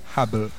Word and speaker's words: Hubble: Hubble.